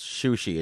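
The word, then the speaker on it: Qiuzi: This was a hard one to pronounce because it's Q-I-U-Z-I, but that's pronounced Shushi.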